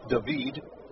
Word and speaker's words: David: James is a Panama native, and I believe the hometown would be pronounced David. Looks like David. That's how it is spelled.